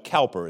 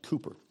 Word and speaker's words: Cowper: It looks like Cowper. It's spelled Cowper, but it's pronounced Cooper. Englishman.